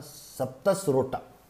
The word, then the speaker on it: Saptasrota: This was done for the satisfaction of the seven great rishis on the banks of Saptarosa, Sapta, let me pronounce this, Sapta, Saptasrota. On the banks of Saptasrota,